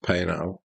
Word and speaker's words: Paisnel: I believe that's pronounced Paisnel. – Is it? – You don't pronounce the ass.